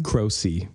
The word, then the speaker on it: Croce: Yeah, or that's like closer to the Italian pronunciation. My family just, I don't know. You can choose how you want to pronounce your name, you know? – So how do you say it again? – Croce. – Croce. – Yeah. Peter Croce. They tried to Americanize it for like reasons.